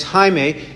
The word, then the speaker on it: Jaime: And that's why we have it spelled this way, J-A-M-E-S. In Spanish, it's Jaime. Is that right? It's Jaime. And why? Because when a Spanish speaker sees a J, they pronounce it like an H. Whereas the J in Latin is supposed to be pronounced like a Y. But in English, when we see a J, we say J. We don't use the Y sound, we use the J sound.